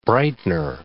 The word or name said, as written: brightener